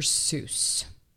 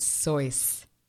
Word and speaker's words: Seuss: It's not Dr. Seuss. It's Dr. Seuss. That one's mind-blowing. It really, really is. It rhymes with voice. So, you're wrong as the deuce, and you shouldn't rejoice. If you're calling him Seuss, he pronounces it Sois.